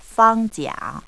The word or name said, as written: fangjia